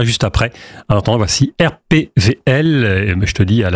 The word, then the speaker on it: RPWL: RPVL. – W. – Ça s'écrit W, mais on dit RPVL. – Ah oui, parce qu'en allemand, le W se prononce pas, voilà. – Exactement.